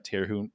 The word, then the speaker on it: Terhune: Brent Terhune? Is that how you say his name? – It's close enough.